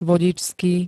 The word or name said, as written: vodičský